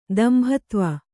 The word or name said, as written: ದಂಭತ್ವ